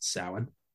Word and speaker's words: Samhain: I did like that they didn't, they pronounced Samhain correctly. It's not Samhain, though it may look like Samhain. It is pronounced Samhain.